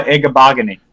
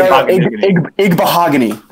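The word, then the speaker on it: Igbinoghene: So if one of you guys want to try to pronounce it. Noah Igbahogany. – There you go. Igbahogany. That's it.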